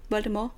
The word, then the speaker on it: Voldemort: øh, så skal Voldemort i virkeligheden udtales med stum T. Voldemort.